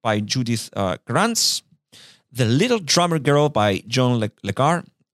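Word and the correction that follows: Carré: Lecar, did I pronounce that right? Lecar.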